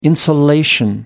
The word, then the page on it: insulation: American English